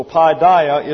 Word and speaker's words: paideia: P-A-I-D-E-I-A, pronounced paideia, and it means the corrective discipline of chastisement.